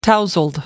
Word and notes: tousled: American English

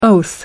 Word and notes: oath: American English

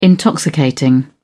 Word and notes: intoxicating: British English

